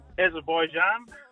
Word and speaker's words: Azerbaijan: Azerbaijan.